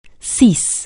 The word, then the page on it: cease: anglais